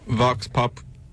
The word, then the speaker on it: Vox Populi: Well, the name of the company is Vox Populi. Am I saying that right? It's Vox Populi. – Yeah, that's, I don't know what, P-O-P-U-L-I, right? – Yes.